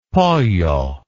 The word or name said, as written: pollo